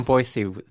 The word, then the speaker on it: Boise: Well, I grew I grew up in Boise.